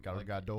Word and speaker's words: Gal Gadot: Like, Gal Gadot or Gal Gadot, whatever, however you say her name. – Gal Gadot.